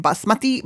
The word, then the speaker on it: basmati: Like walk around saying basmati, basmati? – You actually pronounced it perfectly when you were making a joke out of it. You nailed it.